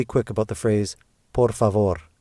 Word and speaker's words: favor: How many syllables are there in the word favor? – Tu favor. – And where does the stress fall, on the first or second? – Favor. On the second.